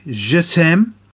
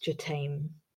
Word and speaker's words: Je t'aime: So, 1983, Je T'aime, Moi Non Plus. Number 84, as Vicious Pink Phenomena. – Oh, so that's their first one. And also, it would be pronounced Je T'aime.